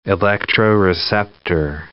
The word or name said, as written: electroreceptor